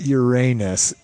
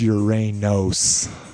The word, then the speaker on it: Uranos: It's not Uranus, it's Uranos. It's the god. And I guess it was spelled with an O.